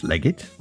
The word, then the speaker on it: Leggett: Sure, that's Leggett. Leggett. – Spell it. – L-E... double G E double T.